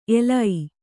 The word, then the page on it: ಎಲೈ: elai